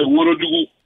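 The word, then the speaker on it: Ouagadougou: Je voulais apporter un petit rectificatif quand vous dites Ouaradougou, mais c'est pas Ouaradougou, c'est Ouaradougou.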